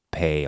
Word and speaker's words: pain: The croissants and pain au chocolat. I think it's pain. Pa, well, the N is not pronounced.